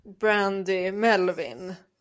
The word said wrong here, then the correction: Brandy Melville: eller Mel... – Ja, precis. Brandy Melville.